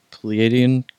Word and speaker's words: Pleiadian: Now, you first started channeling the Pleiadian Collective. Did I say that right? – You did say it right.